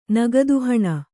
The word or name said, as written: ನಗದುಹಣ